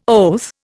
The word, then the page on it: oath: Indian